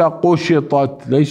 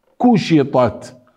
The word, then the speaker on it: كشطت: ليس قشطت، ليست قاف، كشطت، كشي كوشيطات كوشيطات.